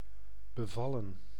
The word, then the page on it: satisfy: American English; /ˈsætɪsfaɪ/